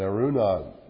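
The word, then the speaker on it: Araunah: And Arunah," which is, I think, the better way to pronounce it, "looked down and saw the king and his servants crossing over towards him.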